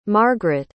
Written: margarett

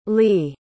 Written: lis

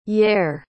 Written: yair

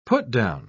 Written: put-down

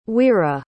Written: wera

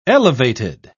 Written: elevated

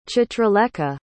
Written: chitralekha

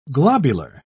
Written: globular

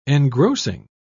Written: engrossing

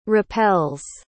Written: repels

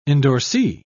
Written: endorsee

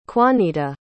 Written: kwanita

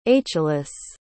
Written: achelous

